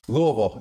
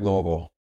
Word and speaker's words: Louisville: Louisville. Yeah. So you got to be able to pronounce it right. Louisville.